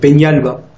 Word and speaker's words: Peñalba: Ouais, il faut mettre avec quand tu as ça le fameux tilde qui veut dire. Bah, tu mets N-I, tu prononces N-I, donc Peñalba.